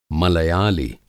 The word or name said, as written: मलयालि